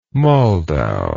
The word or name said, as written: moldau